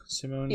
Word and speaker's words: Simone: Simone.